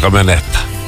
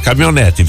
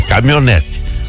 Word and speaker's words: caminhonete: Com a caminhoneta. O jeito certo de falar caminhonete, viu? As crianças não podem aprender errado. É caminhonete. Mas nós fala caminhoneta.